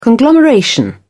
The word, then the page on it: conglomeration: British English